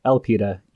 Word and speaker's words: Elpida: Oh, um, uh, Elpida. – Elpida? – Just so I can pronounce it clearly for all of you, Elpida, E L P I D A.